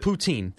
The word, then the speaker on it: poutine: No, that's just poutine. – People have it on the menu down here. I've seen it. – Yeah. – Yeah. – It's a great place. – Is it spelled like the president of Russia? – It's usually P-O-U-T-I-N-E. – Close. – Okay. – Yeah. – Yeah, it's not Putin.